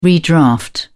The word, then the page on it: redraft: British English